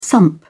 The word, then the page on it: sump: British English